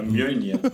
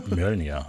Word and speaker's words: Mjölnir: Mimir. – Mjölnir. Mjölnir.